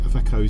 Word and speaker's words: Iveco: Various pronunciations. Iveco. Iveco is probably a good one to start with.